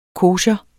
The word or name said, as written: kosher